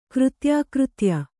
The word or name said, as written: ಕೃತ್ಯಾಕೃತ್ಯ